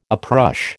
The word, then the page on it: uprush: American English